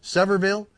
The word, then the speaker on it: Sevierville: Sevierville.